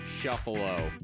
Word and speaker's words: Shuffalo: And today we're talking to Shuffalo, pronounced like Buffalo.